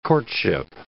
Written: courtship

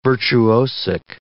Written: virtuosic

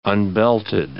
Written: unbelted

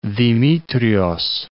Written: Dimitrios

